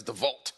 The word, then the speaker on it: DeWalt: How do you pronounce that word? DeWalt with the W? Or is it like German? It's DeWalt.